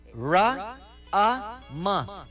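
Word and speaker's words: Rama: Ra-a-ma. Three syllables.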